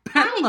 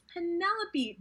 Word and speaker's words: Penelope: Say my name right. – Yes. I just like, like that moment was when it all hit. I'm like, it's Penelope, dude.